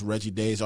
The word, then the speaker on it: Reggie Daes: That's Reggie Days, R-E-G-G-I-E-D-A-E-S.